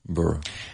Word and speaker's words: borough: B-U-R-R-O-W. So here you have burrow. Burrow. – Burrow. – And then you have B-O-R-O-U-G-H. Pronounced the same as B-U-R-R-O-W. What is a burrow? B-O-R-O-U-G-H.